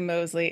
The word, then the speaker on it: Mosley: And now that I say Mosley, is it a long O? – Mosley. Yep. Okay. You got it.